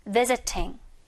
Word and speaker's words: visiting: 자, 마찬가지로 visiting, 방문을 하다에 ing 붙인 형태인데 중간에 t가 이렇게 r 발음으로 미국식에서는 발음이 돼요. 그래서 visiting 이렇게 발음이 됐지만 우리 영국식에서는 t 발음 살려야겠죠? visiting 한번더 visiting 이렇게 발음을 할 거라는 거죠.